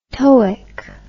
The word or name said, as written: toeic